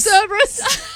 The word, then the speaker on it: Cerberus: It's pronounced Cerberus.